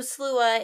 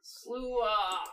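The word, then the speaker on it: sluagh: So slua is spelled S-L-A-U-G-A. Oh, U-A-G-H. Thank you very much. So slua. – Slua. – Big bad slua. Interesting thing about the word, because we're trying to make a point of saying slua, because that is the Gaelic pronunciation.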